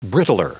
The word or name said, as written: brittler